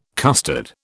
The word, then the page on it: custard: British English